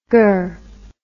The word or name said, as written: gurr